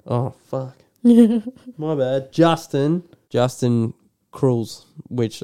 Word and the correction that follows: Kurzel: Justin Kurzel.